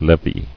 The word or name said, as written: Levied